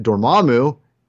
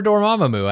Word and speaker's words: Dormammu: And I think his relationship with Dormammu, you're proud of me, I said it correctly. – I prefer Dormammu. I don't think there's ever anything wrong with you saying that.